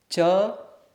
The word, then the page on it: c: sanskrit